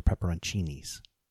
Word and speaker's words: pepperoncini: see CCI in an Italian word, it's pronounced CHI. So those are pepperoncinis.